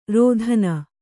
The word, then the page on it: ರೋದನ: rōdana